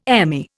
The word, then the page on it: Emmy: Indian